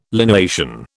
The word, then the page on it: lineation: British English